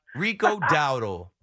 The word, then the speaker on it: Dowdle: Well, I don't even know how to pronounce it. Dowdle? – Rico Dowdle.